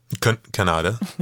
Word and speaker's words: Kanada: And it's actually Kanada? – Kanada. – Yeah, say it right.